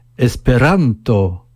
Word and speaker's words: Esperanto: « Esperanto ». Il n'y a aucune exception. En espéranto, l'accent tonique est toujours sur l'avant-dernière syllabe.